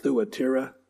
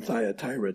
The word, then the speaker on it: Thyatira: Thyatira. I read this week that it's actually pronounced Thuatira, but I can't do that. It's always been Thyatira to me.